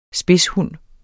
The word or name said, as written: spidshund